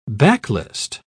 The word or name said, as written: backlist